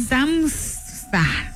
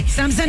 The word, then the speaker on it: SAMHSA: SAMHSA. – ¿Cómo? – SAMHSA. ¿Cómo se dice? – SAMHSA. – Okay. – SAMHSA National Helpline.